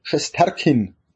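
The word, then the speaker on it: Shesterkin: Und, und das liegt halt auch an Schesterkin, spricht man aus, oder? Ja.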